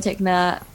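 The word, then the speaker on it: Tickner: And yeah, Instagram is Lauren Tichner. I know L-A-U-R-E, if you want me to spell it, it's L-A-U-R-E-N-T-I-C-K-N-E-R.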